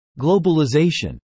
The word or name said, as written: globalization